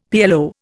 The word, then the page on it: PLO: Indian